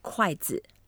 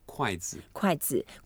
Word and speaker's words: 筷子: The way to say chopsticks, 筷子. 筷子.